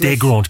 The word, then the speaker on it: Desgrange: Desgrange, because they don't pronounce the S, the French, do they?